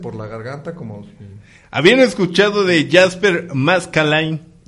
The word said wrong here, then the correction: Maskelyne: Nunca. No. Maskelyne, tal vez porque no lo pronuncié bien.